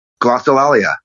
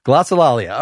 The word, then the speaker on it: Glossolalia: Glossolalia. – Glossolalia.